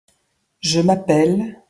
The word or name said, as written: je m'appelle